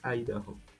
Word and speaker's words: Idaho: ¿Quién es Duncan y, y Dajo? ¿Y ¿Cómo se dice? – Duncan Idaho. Eso, Idaho, gracias.